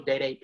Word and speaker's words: Dada: Oh, and check me out on YouTube as well under Official Dede PG. Dede is spelled D-A-D-A and then PG. – So y'all don't be calling him Dada. His name is Dede.